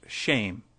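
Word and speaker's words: shame (שֵׁם): which is Shem. Pronounced shame.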